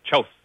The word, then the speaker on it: Kjos: Pronounce that right? – Chos. – Right. K-J-O-S.